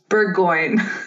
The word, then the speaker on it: Bourgoin: to pronounce it the French way, which is Bourgoin. But you also said that where where you live, uh, they pronounce it Burgoyne.